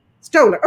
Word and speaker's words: Stoller: Stoller.